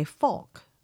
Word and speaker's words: folk: Now, how do you say folk? – Folk. – I say all of these words the same, rhyming with spoke, joke, and broke.